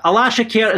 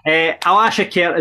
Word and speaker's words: Alashkert: Alaskhert is how we're pronouncing it, isn't it? – Come on.